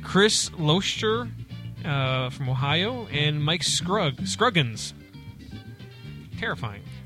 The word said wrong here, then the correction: Scroggins: So, I can't believe that's pronounced Scroggins. He's a lefty. – Yes, he is Scroggins.